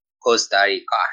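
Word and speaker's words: Costa Rica: کوستاریکا هست درسته من دیگه صحبتی ندارم راجع به کشور کوستاریکا که خیلی سواحل ثروتمندی دارم بله.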